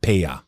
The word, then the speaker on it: Peja: The Serbs pronounce it Pech, but in Albanian it's pronounced Peja.